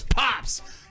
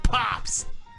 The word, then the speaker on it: Poups: My first name is Pops. You spell that P-O-U-P-S. Like my poops, but it's pronounced Pops. – P-O-U-T-S, you said? – Yes, exactly.